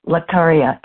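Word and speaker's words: Laccaria: Dr. Greg has written several books and over 120 peer-reviewed journal articles, but his main focus, besides conservation, is the genus Lecaria. Am I saying that correctly, Dr. Greg? – Yes, you are.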